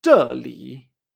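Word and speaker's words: Ché-li (这里): Aquí en chino se dice Ché-li.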